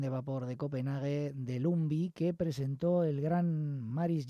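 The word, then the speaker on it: Lumbye: como llamaron a Hans Christian Lumbi. Se escribe Lumbie, con Y, Lumbie.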